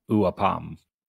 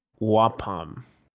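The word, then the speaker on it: Ua'pam: So the way that they pronounce the spren's name in the audiobook is Wapom. – Okay. – Right. I've always heard it as Wapom.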